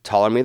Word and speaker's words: Ptolemy: To that end, Ptolemy III, which, by the way, Ptolemy is spelled P-T-O-L-E-M-Y.